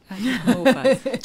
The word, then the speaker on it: anthropomorphize: How do you say anthropomorphize? – Anthropomorphize.